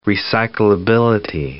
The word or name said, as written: recyclability